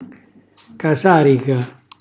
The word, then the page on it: casarica: calabrese